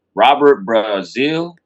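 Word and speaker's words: Brazile: And Robert Brazil.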